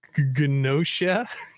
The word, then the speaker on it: Gnosia: Gnosia. yes. I think the G is silent.